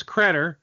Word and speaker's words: Credder: But the last thing I want to ask you is, you know, I know the name's Credder, which rhymes with cheddar.